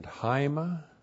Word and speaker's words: haima: Hudor, H-U-D, long O-R. Hudor, that's the Greek word water. That's water and haima. H-A-I-M-A, haima, that's the Greek word blood.